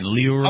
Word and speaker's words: Lieuron: It's spelled L-I-E-U-R-O-N. Luron. – Oh, I heard Huron.